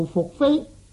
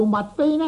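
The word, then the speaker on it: 宓妃: 上面已经讲过福飞相传是伏羲的女，因此啊，伏羲的伏字呢，实际上和杞人边作一个犬字嗰个埋伏的伏字呢是相通的，所以呢读做伏飞就是比较正确啲嘅。假如读做物飞呢，咁就是一个不正确的读音嚟。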